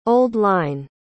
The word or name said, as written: old-line